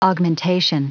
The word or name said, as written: augmentation